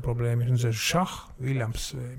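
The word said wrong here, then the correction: Zach Williams: Zack. Nagu Zachariah, nagu Zakarias. – Zachariah, okei, okay. No võt näed. – Zack Williams.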